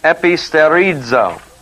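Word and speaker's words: episterizo (ἐπιστηρίζω): And the word strengthen is episterizo. Do you remember that word? We had it before. It's E P I S T long E R I Z long O.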